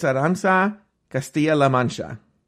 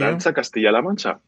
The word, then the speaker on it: Arantxa Castilla-La Mancha: I want to say it's Arantxa Castilla-La Mancha. – Yes, very well pronounced. – Thank you. – Arantxa Castilla-La Mancha, yeah.